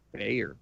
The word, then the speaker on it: Beyer: Bayer. – Bayer, uh, spelled like the company Bayer. – Oh, the B A E R or whatever that is. Mm, yeah, B E Y E R.